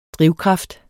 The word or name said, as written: drivkraft